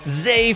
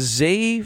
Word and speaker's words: Zah: Zay Frazier out of UTSA. That's right, Zay. It's spelled Z-A-H, so it looks like Za, but it is Zay Frazier,